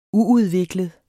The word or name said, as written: uudviklet